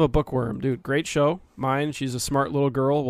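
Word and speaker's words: Myne: Waifu is it mine? Is that how you say it? – It's mine.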